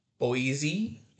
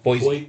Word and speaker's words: Boise: Chad has made his return from Boise. Yeah, I said it right this time. – Boise.